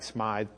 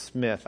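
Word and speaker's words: Smyth: And this is what Jonathan Smith, uh, it looks like Smythe, but it's Smith, I think is the way they pronounced it.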